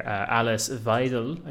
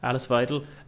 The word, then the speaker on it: Weidel: The AfD co-leader, Alice Weidel, I think it's pronounced, Alice Weidel,